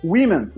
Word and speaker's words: women: Você escreve F-I-S-H. É fácil, não é? – Sim. – Aí você tem uma palavra que é women. Women, você escreve W-O... M-E-N. Por que, que tem um O e você fala I, igual fish? – Entendeu?